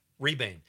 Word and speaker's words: Rebane: But yeah, mostly about the director, Bill Redbane, I think. Okay, that's how you say it. Rebane.